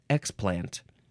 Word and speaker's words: explant: The next word is the second form of explant, which it looks like you emphasize the first syllable, explant. The first form, we emphasize the second syllable.